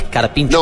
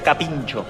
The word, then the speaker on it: carpincho: Porque para mí es carpincho. – No, la gente de campo, como yo, le decía capincho. Se le pronuncia de las dos maneras, pero en el arco decía capincho.